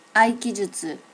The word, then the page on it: aikijutsu: native Japanese